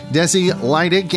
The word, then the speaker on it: Desi Lydic: The Daily Show with Trevor Noah presents Remembering RBG, A Nation Ugly Cries with Desi Lydic. And if I mispronounce any part of that name, I'm profusely apologize for that. – You got it perfect. You pronounced it perfectly.